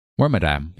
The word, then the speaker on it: Wormadam: I would argue that that Wormadam, or however you say its name. I think that's how you say it. – Wormadam, yeah.